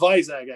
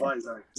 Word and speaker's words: Vizag: It's Vizag, actually. – Vizag. – I think they pronounce it Vizag.